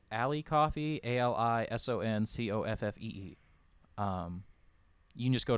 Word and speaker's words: Ko-fi: It's spelled like Ko-fi, but I think it's supposed to be pronounced coffee.